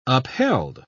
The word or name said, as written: upheld